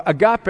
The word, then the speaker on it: agape: The agape, or agape as some pronounce it, is not that self-seeking love.